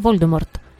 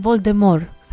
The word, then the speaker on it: Voldemort: Το όνομα Voldemort, όλοι το προφέρουμε Voldemort. Κι όμως η Rowling είπε ότι το τελευταίο τάφ είναι άϊχο. Δηλαδή κανονικά είναι Voldemort.